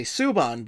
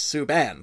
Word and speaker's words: Subban: Subban, who, again, somebody who, much like Nazem Kadri, I have trouble pronouncing because it's always Subban, and I always say Subban because that's just how my brain thinks it should be pronounced. And it's like, no, it's Subban. It's like, okay, I got to think Canadian with this.